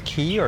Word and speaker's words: Key: K, Key. Some people have different pronunciation. Key. I've probably been saying it wrong this entire time trying to introduce this place when we were talking about recording here yes, on well, our last update. – En français, it is K.